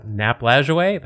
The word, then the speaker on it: Lajoie: and his last name is Lajoie. Did I say it right, do you think? – That's Nap Lajoie? That's how I say it.